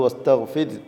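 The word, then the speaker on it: وَاسْتَغْفِرْ: അപ്പോൾ വസ്തഗ്ഫിർ എന്നത് വസ്തഗ്ഫിർ എന്ന തർക്കീഖിൻ്റെ ഉച്ചാരണം ആണ് അവിടെ വേണ്ടത്.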